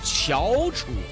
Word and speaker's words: 翘楚: 电视剧《翘楚》的主题曲，好像普通话最标准发音应该读“ 翘楚二声”。